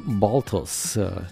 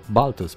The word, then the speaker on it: Balthvs: Ei spun Baltos, dau și indicații cum să pronunți în limba engleză, iar în spaniolă spun ei pronunță-l Baltos, pentru că se scrie B-A-L-T-H-V-S.